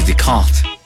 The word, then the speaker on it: Descartes: El error de Descartes. Ajá. Es que luego me acuerdo que así se pronuncia y es de lo poco que sé de francés.